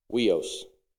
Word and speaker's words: huios: And then the other definition is actually son, and that's it's spelled H U I O S, but it's pronounced Weos.